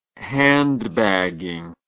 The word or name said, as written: handbagging